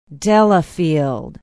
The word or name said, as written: Delafield